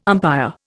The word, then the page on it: umpire: Indian